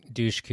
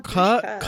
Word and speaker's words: Dushku: I think Dushku is the proper pronunciation. – Like, ooh, there's a U at the end, yeah. – It's not Dushka. Ka? Why can't it be Ka? – There's Dushka? That would be an A.